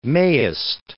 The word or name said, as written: mayst